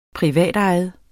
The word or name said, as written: privatejet